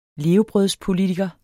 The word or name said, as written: levebrødspolitiker